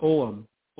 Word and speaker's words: ogham: It turns out to be a sun symbol, and he called it, and you mentioned the word ogham, and that's what I always pronounce it, but Maria Wheatley calls it oum. Oum. – Okay. – It doesn't look like the way it's spelled, you know.